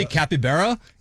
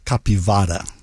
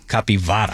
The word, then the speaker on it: capivara: Because when I say capybara, it's like I'm ordering a smoothie at a bar in Oceanside or something. – The correct pronunciation's capivara. – Capivara.